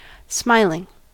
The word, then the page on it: smiling: American English